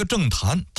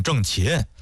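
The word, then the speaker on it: 郑覃: 关于这个郑谭，谭字怎么写呢？上边一个东西的西，下边一个早晚的早，就是上西下早，这是一多音字啊。你要是网上查的话，有人说念郑谭，有人说念郑琴，这两边还打呢。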